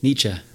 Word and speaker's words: Nietzsche: Yeah, I was going to say a lot of my audience is Christians and they only know, now I pronounce his name wrong, Nietzsche, but how do you say it? – Nietzsche. – Nietzsche, Friedrich Nietzsche.